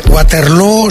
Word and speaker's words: Waterloo: Bueno, lo primero, Waterloo se pronuncia igual que Feijóo.